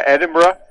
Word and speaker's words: Edinburgh: Isn't it the way the Scots, pretty close? Isn't that how they pronounce it? – That's right. Yeah, Edinburgh.